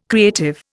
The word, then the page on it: creative: Indian